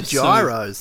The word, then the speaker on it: gyros: But I think it's spelt with a G, but it's pronounced like euro... But you're in Europe. – Yeah, gyros.